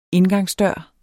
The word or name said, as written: indgangsdør